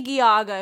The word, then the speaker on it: Geauga: Geauga County is pronounced Geauga, although I much prefer Lady Gaga County.